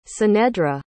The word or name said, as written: cenedra